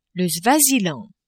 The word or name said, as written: Swaziland